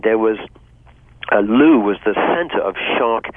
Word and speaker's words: Looe: It's Loo, which is spelled Looe, I believe.